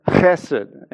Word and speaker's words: Hesed: This particular word for loving kindness is the Hebrew word Hesed, and when you say it correctly,